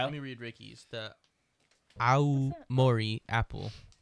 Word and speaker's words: Aomori: But it's Aomori.